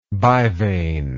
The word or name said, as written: bivane